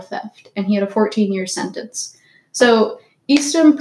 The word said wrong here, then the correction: Eastham: Prison Farm, I don't know if I'm pronouncing that right, Eastham, anyways, it's in Texas, and it was really bad.